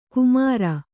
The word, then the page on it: ಕುಮರ: kumara